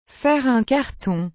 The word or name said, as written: faire un carton